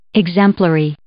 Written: exemplary

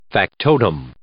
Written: factotum

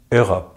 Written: Europe

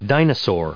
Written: dinosaur

